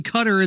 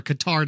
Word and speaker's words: Qatar: Because that's going to be in Qatar, and they've killed, or Qatar, depending on how you want to pronounce it.